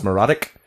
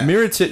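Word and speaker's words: Mirotić: I actually, if I had a close second to Andrew Wiggins, it would definitely be, I believe it's pronounced Mrotić. From Chicago. Miritich. – Yeah. – Miritich. It's not pronounced Mrotić at all.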